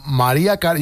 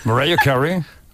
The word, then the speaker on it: Mariah Carey: María Carey, yo te lo digo en castellano y tú en cómo se dice bien. María Carey. – Mariah Carey.